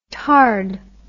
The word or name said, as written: tard